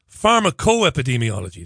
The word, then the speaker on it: Pharmacoepidemiology: Pharmacoepidemiology. That's the one. Stephen Evans. And he's Professor of Pharmacoepidemiology at the London School of Tropical Medicine.